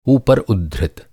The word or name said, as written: ऊपर